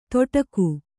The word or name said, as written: ತೊಡಕು